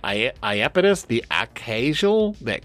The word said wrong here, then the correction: Acausal: that would be Iapetus the Acausal. – Sure. – As in Iapetus the either has no cause or behaves in ways that have no cause or is related to having no cause.